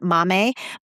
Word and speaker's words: Maame: is called Mame, M-A-A-M-E, but you pronounce it Mame, by Jessica George.